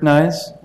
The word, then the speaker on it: Nys: My name is Rick Nyes, last name is spelled N-Y-S.